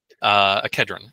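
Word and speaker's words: Kidron: Following the river along to get to the Valley of Kelron. Kedron, excuse me. I keep wanting to mispronounce that.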